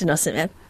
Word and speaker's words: denosumab: You were involved in a study that showed an osteoporosis drug, denosumab, is it? How do I say it? – Yes, denosumab.